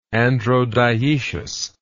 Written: androdioecious

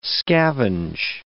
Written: scavenge

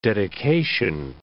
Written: dedication

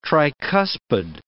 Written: tricuspid

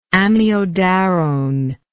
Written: amiodarone

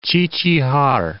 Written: Qiqihar

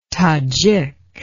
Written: tadzhik

